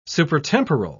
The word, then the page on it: supertemporal: British English